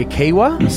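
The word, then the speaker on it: Kiewa: Now, some people say Kiwa. Some people say Kiwar. I personally say Kiwa. – Oh, I think you say it right, actually, now. Has he finally learned how to say Kiwa? – Hey.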